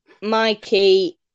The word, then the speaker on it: Mikey: No, not Milky. I'm more about like Mikey.